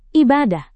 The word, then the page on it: ibadah: Indonesian